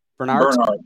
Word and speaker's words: Bernhardt: Jesse, I can't say your last name. Bernard? – Bernard. Yeah. Bernard.